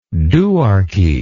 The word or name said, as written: duarchy